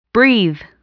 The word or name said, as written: breathe